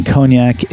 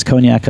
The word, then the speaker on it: Cognac: We're about to hear how it's pronounced. Oh, I've got to turn up my volume probably. Here we go. Let's try it again. – Cognac. – Cognac. Cognac. – So all we were close.